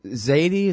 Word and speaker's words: Zaydi: Well, and, you know, I read that he actually is a Zaydi. Is that how you say it? Do you know? – Right.